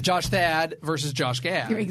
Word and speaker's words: Gad: Another really important part of that, the story that we haven't touched upon yet, is that Gad rhymes with Thad.